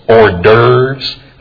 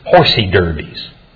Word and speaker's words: hors d'oeuvres: I have a friend in Wisconsin that calls hors d'oeuvres horsey derbies.